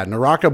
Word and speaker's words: Naraka: Yeah, Naraka Blade Point.